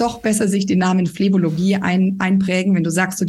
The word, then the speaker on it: Phlebologie: Und habe ich Phlebologie richtig ausgesprochen? – Phlebologie klingt genau, wie es sein sollte.